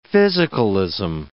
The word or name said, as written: physicalism